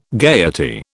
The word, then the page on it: gaiety: British English